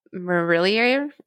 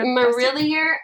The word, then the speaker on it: Marillier: Juliet Marillier. Is that how you say it? Marillier. Um, let me just. I, uh, yeah, M A R I L L I E R.